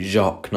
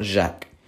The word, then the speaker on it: Jacques: As I pronounce it anyway, Jacques, not Jack. Not Jack. Not Jack, Shaq. It's Jacques. Jack. You get me? What did I say? It's Jack. No, it's Jacques.